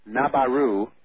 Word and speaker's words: Nibiru: and I'm referring to Nibiru. Nabaru is how Morinne would pronounce it, that Sitchin talks about.